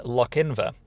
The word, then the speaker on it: Lochinver: Interesting, last week I was in Lochinver. That is how you pronounce it, isn't it? – I think so, yeah.